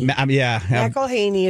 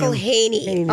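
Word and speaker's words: McElhenney: McElhaney is how you say his name. – McElhaney.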